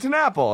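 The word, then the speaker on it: TenNapel: How do you say it? And he's like, Doug Tenapple. And I was like, yes, okay. – Yeah, because it's... – I didn't say that wrong.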